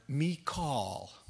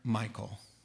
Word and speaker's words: Michal: Now Saul's daughter, Michal, is how you say the word in the Hebrew. Everyone calls her Michal. It's Michal. But I'll call her Michal just so we're all comfortable.